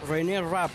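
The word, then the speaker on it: Reneé Rapp: René Rapp, ¿lo estoy pronunciando bien? – Es como René de Calle 13, pero sí, se escribe distinto.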